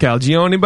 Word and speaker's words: Calagione: And he just said, it's like bologna. – Perfect. – And that's how I've remembered it ever since. – Perfect. – Calagione bologna.